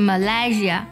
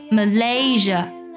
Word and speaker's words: Malaysia: 下一个呢就是马来西亚了，不少同学呢受到了中文音译的影响，马来西亚，所以是 Malaysia 啊，其实不是这样去读的。那么这里呢，它的第二个音节 LAY 它读作 lay，而且重音呢也在这个上面，读作 Malaysia。